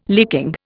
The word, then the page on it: licking: Indian